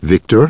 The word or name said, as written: Victor